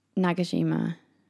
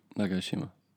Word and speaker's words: Nagashima: Nagashima. Sorry, I said that weird. Can you say it properly? – Nagashima.